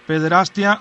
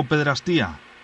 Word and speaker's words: pederastia: ¿Pederastia o pederastía? – Es pederastia, sin acento en la I. – Que siempre decimos pederastía, ¿no? – Exactamente, entonces se va difundiendo el error, pero es pederastia.